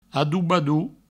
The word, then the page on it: adobador: adoubadou